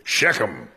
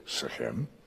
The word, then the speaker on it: Shechem: Now, typical American pronunciation is Shechem. Because we put the emphasis on the first half of a word, of first syllable, where in Hebrew it's the second half for them. So they would say, Shechem.